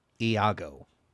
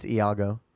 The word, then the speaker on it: Iago: It's pronounced Iago. – Yeah, that's a capital I, and it's Iago.